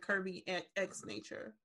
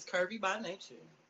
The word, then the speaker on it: Curvy by Nature: So it's Curvy by Nature, because that's how I say it, or is it Curvy X Nature? – No, you said it correctly. Yeah, it's Curvy by Nature.